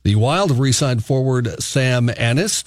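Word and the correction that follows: Anas: On the release, they said, pronounced Annas.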